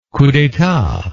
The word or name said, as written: coup d'etat